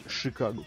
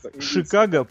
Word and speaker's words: Chicago: Правильно говорить, Chicago, кстати. – Это ин- индийское что-то? – Индийское. Индийское, да-да-да, нормально. – Ш- Шикаго, по, да, под, подогнал под актуал, подвязался. Шикаго по-индейски означает «деревня».